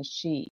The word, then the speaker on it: Sidhe: They call them the Shi. It's spelled S-I-D-H-E.